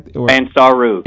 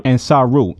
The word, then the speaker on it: Ansaru: Is it Ansara? Am I right? – Ansaru. – Ansaru. Ansaru.